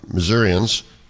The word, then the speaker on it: Missourians: and Missourians, is that how you say it? – Yep, sure.